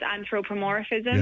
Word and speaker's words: anthropomorphism: But like you were saying about humanizing, or what's that word, anthropomorphism? – Yeah, I couldn't pronounce it. I went with humanizing. – I think I'm pronouncing it right. It's anthropomorphism. – Yes.